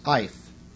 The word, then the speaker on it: eth: Now, the Hebrew word for a season of the year, such as winter or summer, is ETH, pronounced ith.